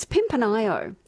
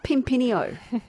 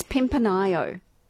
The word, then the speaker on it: Pimpinio: And it's Pimpinio. It's not Pimpinio. It's Pimpinio. – Mm. – So there are those, you know, but I guess those in Pimpinio know that.